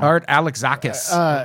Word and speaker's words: Art Alexakis: How do you say his name? – Art Alexakis. – I was so close.